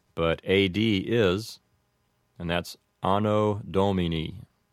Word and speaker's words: Anno Domini: I suppose that a more correct pronunciation would have the long vowels like this, Anno Domini. But the way you might commonly hear this, pronounced with an Americanized pronunciation, might be Anno Domini.